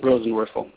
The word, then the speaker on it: Rosenworcel: Because you have Commissioner Worst, I have the worst time pronouncing it. – Rosenworcel. – Thank you very much there.